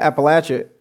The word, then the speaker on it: Appalachia: So when you think about Appalachia, one of the biggest experiences that happened in this past couple of years, it was a fight called the Mountain Valley Pipeline fight. And all across Appalachia in Tennessee,